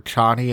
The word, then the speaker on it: Chani: Ch- Cheney dies in, uh, and I like, by the way, that they pronounced her Chani in the movie because I always think of Dick Cheney.